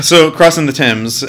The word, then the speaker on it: Thames: It's pronounced the Thames, right? – The Thames. Yeah, it is the Thames.